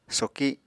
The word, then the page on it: soki: Apatani